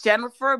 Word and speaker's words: Jennifer: It's Jennifer Bellany, and it's Jennifer with two N's.